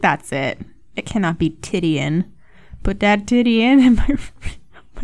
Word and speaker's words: Titian: Titian.